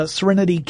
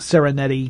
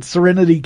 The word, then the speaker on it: Sereneti: Sereneti is spelled S E R E N E T I. – Yeah, uh, looks and, like Serengeti. – Yeah, yeah. I keep wanting calling calling it a Serenity Kitchen, but Sereneti Kitchen.